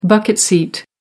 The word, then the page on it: bucket-seat: American English